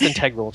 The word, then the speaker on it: integral: It's pronounced integral.